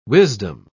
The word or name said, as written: wisdom